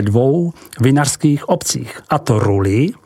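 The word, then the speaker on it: Rully: Od obce Buzoron je to jenom malý kousek k prvním vinohradům Rulili, vyslovuj Rulí.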